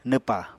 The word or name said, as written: nwpa